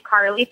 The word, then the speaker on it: Carleigh: My email is my first and last name, Carleigh Fedorka, but it's spelled kind of funny.